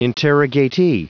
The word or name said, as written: interrogatee